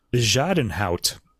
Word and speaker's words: Bezuidenhout: Anyway, so Rachel Podger on the violin, and the pianist here, and I looked this pronunciation up, Christian Bejadenhout. Christian Bejadenhout. That's a Dutch name, and he is born in South Africa.